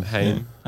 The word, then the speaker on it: Haim: Is it kind of Haimi? – Female guitarist. Yeah, a bit Haimi. – Do you say Haim? Is that how it's pronounced? – Haim. Haim. – Yeah. Haim. – I think it's Haim.